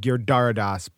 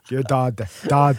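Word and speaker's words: Giridharadas: Now, I said Girdardas, but the R wasn't pronounced, but that's because I have a New York accent. That wasn't the best. Girdardas.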